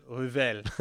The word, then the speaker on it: Revel: Thank you so much, Professor Ariel Revel. – you pronounce it well. – Oh, sweet. – The French way.